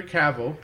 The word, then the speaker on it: Cavill: We'll yeah, and they got Henry Cavill, am I pronouncing it? Yeah, Henry Cavill, he's playing Sherlock.